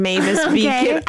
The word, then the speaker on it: Mavis Beacon: And then on the screen, everyone took the Mavis Bacon, Mavis Beacon. I honestly am saying it wrong because I think we all called it something wrong.